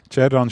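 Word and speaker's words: Xherdan: auch nicht der Cerdan Shaqiri, der für Coop Werbung macht.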